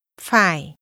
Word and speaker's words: phải: Phải. Có phải là. Ông có phải là người Mỹ không? – Phải is pronounced with a falling, rising tone.